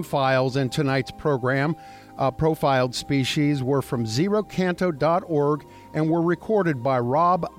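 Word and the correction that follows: xeno-canto: It's ZenoCanto. – ZenoCanto.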